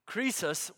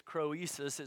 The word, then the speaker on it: Croesus: Croesus, which is the king at the time period, you may think it's Croesus, it's just Croesus is the easiest, the best pronunciation probably.